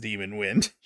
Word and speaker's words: Demon Wind: You put the emphasis on the wrong syllable. – It's Demon Wind. – It is Demon Wind.